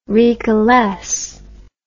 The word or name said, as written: recalesce